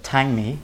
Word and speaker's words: Tangmi: I'm going to pronounce this wrong because there was an emphasis there, but Tangmi. – Yes, correct.